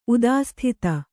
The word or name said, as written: ಉದಾಸ್ಥಿತ